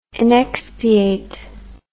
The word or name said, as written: inexpiate